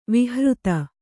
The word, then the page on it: ವಿಹೃತಿ: vihřti